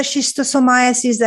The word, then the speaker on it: schistosomiasis: You're also interested in, it's called snail fever, and I will potentially butcher this word, schistosomiasis.